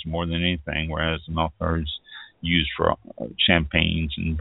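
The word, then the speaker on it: Melchior: Melchor, I believe it's pronounced. I think it's a hard C on that.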